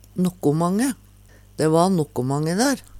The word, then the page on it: nokkomange: Numedalsmål